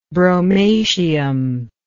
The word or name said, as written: bromatium